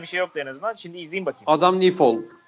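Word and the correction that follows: Nyfjäll: Ni, pardon Nifjol. Nifjol. Bunlar çok garip okunuyor ya.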